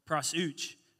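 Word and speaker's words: prosuch (προσευχή): and the Greek word for prayer is called prosuch. And I know it sounds a little funny, but I promise that's how you pronounce it because I Googled that too.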